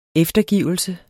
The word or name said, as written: eftergivelse